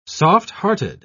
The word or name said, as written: soft-hearted